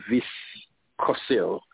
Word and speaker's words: Vyskocil: So this judge, Judge Mary Viscol, her last name is spelled V-Y-S-K-O-C-I-L, Viscosil,